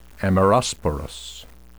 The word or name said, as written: amerosporous